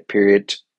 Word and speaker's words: periodt: It's period with T on the end. And so I like to do to pronounce it correctly and say period, you know, because you got to add that T.